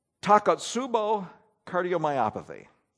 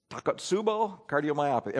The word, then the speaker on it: Takotsubo cardiomyopathy: Takotsubo cardiomyopathy. One knows. Anybody else? Takotsubo cardiomyopathy.